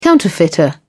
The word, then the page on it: counterfeiter: British English